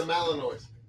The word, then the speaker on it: Malinois: It's a Belgian Malinois? – Malamute. No, not Malamute. Let me see. – Malinois. – Oh, it's a Malinois. – Okay. – Malinois.